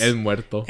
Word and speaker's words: El Muerto: El Muerto. – And I said it right? – El Muerto, yeah.